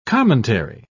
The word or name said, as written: commentary